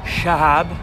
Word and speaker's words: Shahab: His name is Shahab. – Shahab. – It rhymes with Bob, he would say.